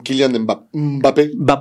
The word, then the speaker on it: Kylian Mbappé: Kylian Mbappé.